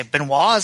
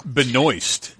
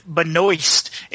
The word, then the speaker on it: Benoist: The Melissa, what do you say, Benoit? Is that how you pronounce her last name? Supergirl. – Benoist. – Benoist.